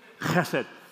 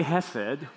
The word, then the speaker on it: Chesed: Chesed, you don't actually have to put guttural in there. I know last week Pastor Chris said you have to actually cop up some stuff to actually say this word. Chesed. Even though that's a proper way to say it, you can say chesed, like without guttural, and you know, if you talk to Israelis or Jewish people today, they'll say, I understand what you're saying.